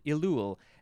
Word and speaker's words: Elul: The next word is elul.